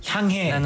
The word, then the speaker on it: 향해: 네, 그 히읗 발음이 좀 세거든요. 향해.